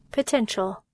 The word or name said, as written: potential